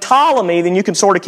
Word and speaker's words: Ptolemy: I know you can't use phonics to pronounce that name. It starts with a P. It's not Ptolemy. It's Ptolemy.